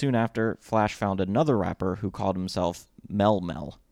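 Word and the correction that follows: Melle Mel: It's possible I mispronounced it because it's M-E-L-L-E-M-E-L. So Mele Mel? – That would be better. – Okay. Mele Mel then.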